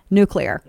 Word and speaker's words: nuclear: Because in Texas, that's how everybody says nuclear. Nuclear. N-O-O-K. Nuke. U-Y-O-U. Lur. L-U-R. It's just the Texas thing. It's how they say it. They're not stupid. It's an accent.